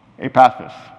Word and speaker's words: Epaphras: So now I'm totally confused, but Epirusa, how do you pronounce that? Huh? Epaphras. Yeah, okay, we'll try that one.